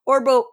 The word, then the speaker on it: Orgbo: Orbo, which is spelled strangely, O-R-G-B-O, but we just pronounce it Orbo.